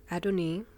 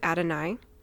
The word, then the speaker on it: Adonai: It's Adonai or Adoni. See, I'm already doing it. I'm already talking about the different pronunciations. We're going to stick with Adonai.